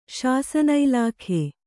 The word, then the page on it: ಶಾಸನಇಲಾಖೆ: kannada; śasana ilākhe